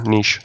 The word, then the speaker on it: niche: very particular niche case. So, and sorry, I'm Canadian there, niche. I'm to for your American viewers, niche.